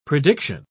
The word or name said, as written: prediction